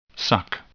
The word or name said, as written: suck